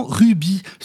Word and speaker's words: rugby: Dans le sud-ouest, berceau historique du ballon ovale, on entend très souvent rugby, sans le « ge » ou le « de », une prononciation adaptée aux règles phonétiques du français local, qui n'aime pas quand trop de consonnes se succèdent.